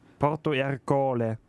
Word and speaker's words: Porto Ercole: 1608 flieht er quasi von Malta und 1610 stirbt er in Porto Ercole. – Also in irgendeiner Hafenstadt höchstwahrscheinlich. – Ja. Ja. Willst du nochmal die Aussprache kontrollieren? – Stimmt. Alles gut. – Porto Ercole.